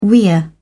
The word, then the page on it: weir: British English